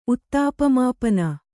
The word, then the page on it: ಉತ್ತಾಪಮಾಪನ: Kannada